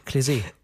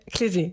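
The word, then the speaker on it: Klez.e: Und zwar einmal von der Berliner Band Kletz. Jetzt wollte ich dich vorher noch fragen. – Klesee. – Klesee, genau. Ich habe schon wieder vergessen, wie sie ausgesprochen werden.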